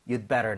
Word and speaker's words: You'd better: So 여기서도 you had better 보다는 you'd better not. – You'd better not. – Alright? You do인데 do 사운드가 거의 안 나요. You'd better. You'd better.